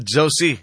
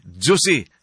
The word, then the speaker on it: Josie: Para to si Ma'am, si Sir Manny Janagbay, kaganina murag na, kwa nang, na naipit lang yung dila, na Jose niya. Pero ang yung pag-pronounce naman, Josie. Josie. Kay kwa naman, G-O-S-I-E.